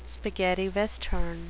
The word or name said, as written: spaghetti western